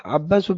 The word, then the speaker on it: عباس: ترجمة: عباس بن الوليد وعياش بن الوليد، وهذان شيخان مشتبهان في الاسم خطا، مختلفان نطقا، متفقان في الأب خطا ونطقا، مختلفان شخصا، فالأول بالباء الموحدة والسين المهملة، والثاني بالياء المثناة تحت والشين المعجمة، وقد أوضحت أمرهما في الفصل الماضي فليراجع منه.